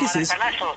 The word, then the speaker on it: Maracanazo: ¿Maracanazo? – ¿Qué Maracanazo, sí.